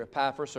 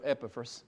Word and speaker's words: Epaphras: Excuse me. Epaphras. It's pronounced either Epaphras or Epaphras.